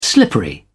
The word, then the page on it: slippery: British English